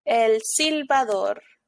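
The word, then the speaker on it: El Silbón: El Silbador. – Thank you so much. I'm not going to be able to like replicate that. So, but for at least that's how it's supposed to be pronounced.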